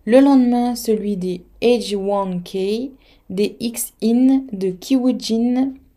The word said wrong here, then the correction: Kim Woojin: Kim Woojin, je l'ai mal prononcé, et des Pentagon, et c'est un retour japonais.